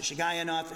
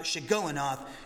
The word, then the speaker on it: Shigionoth: And uh, you can pronounce it Shigayanov. Shigoyanov is actually the best way to pronounce it.